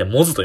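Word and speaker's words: 百舌鳥: えー、ひらがななんですけど、そもそも、え百 100 の下で鳥と書いてモズと読むんですよね。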